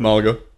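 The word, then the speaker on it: Málaga: You said Malaga? I always pronounce it wrong. – Yep, Malaga.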